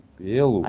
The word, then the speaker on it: белу: Мы, э, значит, белу это ну, не туда ударение, это ударение идет на у, нам оно здесь не нужно. А у нас э, ударение идет на, на бе, на е.